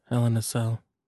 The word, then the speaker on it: Hell in a Cell: So this is the go home show before Heck in a Sec. – Yes. – And Hell in a Cell.